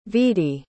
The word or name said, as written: vedie